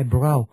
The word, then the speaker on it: brow: ngày xưa là cái chữ đó là đọc là brow hay là bro tùy vần thơ.